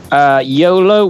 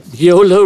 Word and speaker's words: YOLO: YOLO wasn't a waste of time getting into. That sounds like it's on track to be good. It rhymes with polo.